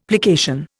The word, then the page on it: plication: Indian